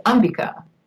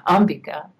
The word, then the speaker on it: Ambika: He said, "It's Ambika." That's how they say it in India, Ambika.